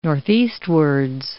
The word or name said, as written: northeastwards